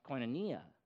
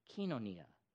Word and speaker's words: koinonia: I've always heard it pronounced koinonia. koinonia, but I checked, and they're pronouncing it kinonia, kinonia. What do you like? What do you prefer? Koinonia. Let's run with that, okay?